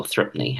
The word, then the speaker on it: Threepenny: is it Threepenny or Threepney? How do you say it? – Threepney.